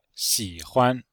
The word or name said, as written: xi3huan5